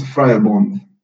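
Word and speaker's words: Vrijbond: Sau mă rog, să-mi spui, te rog, cum se pronunță, că olandeza mea e oribilă. – Nici o mai nu-i foarte deosebită, dar se pronunță Friebund.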